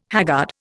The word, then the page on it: haggard: Indian